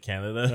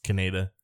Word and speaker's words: Kaneda: In the Japanese version, when you watch it, when they say his name, it's straight up I think it is pronounced like Canada. – Uh-huh. – But the way it is in, I think, both dubs. I haven't watched the 2001 Pioneer dub, I don't think. It's Kaneda.